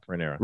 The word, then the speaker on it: Rhaenyra: Rhaenyra. Rhaenyra.